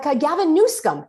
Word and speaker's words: Newsom: Like Gavin Newsom, yes, that's how you pronounce it.